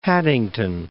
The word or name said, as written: Haddington